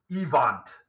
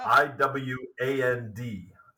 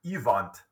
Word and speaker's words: Iwand: I W A N D. So, kind of looks like Iwand or Iwand, um, but but he would have pronounced it Ivant uh, as a, a good German boy.